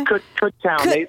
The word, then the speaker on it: Kutztown: Is it Kutztown? Is that how you pronounce it? With that Z in there? – Kutztown. They always get wrong.